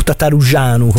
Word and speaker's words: Tătărușanu: E altro portiere, quindi il secondo portiere rumeno che abbiamo avuto è Ciprian Tătărușanu, o Tătărușanu come se lo vogliamo pronunciare un po' alla rumena.